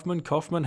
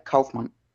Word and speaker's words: Kaufman: Am I saying that right? Kaufman? How do we say it? – Yeah, Kaufman.